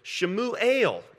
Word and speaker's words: Shemuel: And this kid is named, I'm going to pronounce his name in Hebrew, Shemuel is his name.